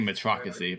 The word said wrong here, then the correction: meritocracy: but I can't say it right now. – Meritocracy. – Sorry, I was like, I'm missing a syllable.